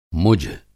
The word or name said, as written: मुझ